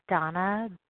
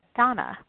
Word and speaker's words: Dana: This is Donna B, as in boy, calling from Denver, Colorado. My name is spelled D-A-N-A, but pronounced Donna.